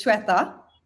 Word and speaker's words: Swetha: Because the way that I would say, if I'm looking at it, it's Swatha. But I know that's not even close. And I screw it up and I get corrected and then I have to say it like five more times. So yeah. I'm gonna let you pronounce it first and then. – Okay, we'll so it's Shweta. – Right. – Yeah, but it doesn't sound anything like the way it's spelled. So I will give you that, Brian.